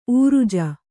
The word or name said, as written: ಊರುಜ